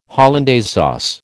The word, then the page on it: hollandaise sauce: American English